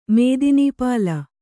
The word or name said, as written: ಮೇದಿನೀಪಾಲ